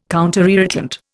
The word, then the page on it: counterirritant: Indian